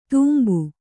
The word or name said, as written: ತುಂಬು